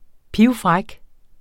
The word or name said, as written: pivfræk